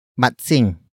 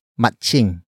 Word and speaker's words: matsing: The next word is matsing. Matsing. Matsing. Matsing is spelled as M-A-T-S-I-N-G. It means monkey, the actor who will peel the banana. Some Tagalog speakers would pronounce it as matching. Matching. Matching. The next word is ang, which is spelled as A-N-G.